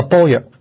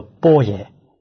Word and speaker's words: 般若: 这个波若有时候叫做波耶，读音有些不同，它也是梵文，翻译成中文的意思就是智慧，这个都是尊重不凡。